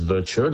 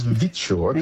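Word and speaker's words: Viture: There's one that's popped up in Australia called Vitture. I keep pronouncing it as Virtue. No, it's Vitture.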